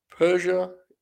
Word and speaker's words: Persia: Persia, is that correct? Did I say that correctly, Bob? – Yes.